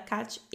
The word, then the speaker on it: Kate: Ela tinha um Instagram, agora não tá mais disponível, mas o nome do Instagram dela era Kat e a Luz ou Kate a luz. Tem pessoas que pronunciam diferente, porque é K-A-T-E a luz. Então a gente fala Kate a luz e Kat e a luz.